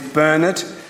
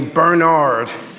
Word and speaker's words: Bernard: If you haven't met me, my name's Bernard. That's the British pronunciation. But feel very free to call me Bernard if you find that easier.